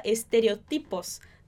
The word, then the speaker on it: estereotipos: Em espanhol a gente fala estereotipos.